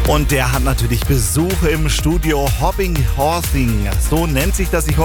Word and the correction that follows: Hobby Horsing: Ich habe gerade gesagt, Hobby Horsing. Habe ich schon mal richtig ausgesprochen? – Ja, jetzt war es richtig. Hobby Horsing heißt es.